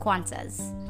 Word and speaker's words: Qantas: Just like in airlines called Qantas. Most of Indonesians pronounce it like Qantas, but it's supposed to be pronounced Qantas.